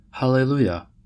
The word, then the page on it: hallelujah: American English